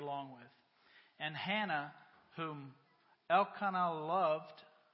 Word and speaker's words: Elkanah: It's Elkanah, the man of this house.